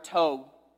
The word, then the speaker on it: tov: The idea of God's goodness or his functional faithfulness, it comes from this Hebrew word, tov, which sounds like stove without the S. It's spelled T-O-V. Tov.